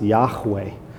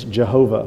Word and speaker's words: Yahweh: We don't know the vowel points, but Hebrew scholars think it's probably pronounced Yahweh. You know, in the King James, it's Jehovah, which is really just a German way. I mean, what is J in German? It's Yeh. So there's never been Jehovah. The German scholars put down Yehoah. So Jehovah's wrong. It's a total mispronunciation.